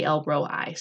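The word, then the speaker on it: El Roi: "You are Elroy," and that may be pronounced Elroi. I have always thought it was Elroy, but I think it may be Elroi. So let's say Elroi.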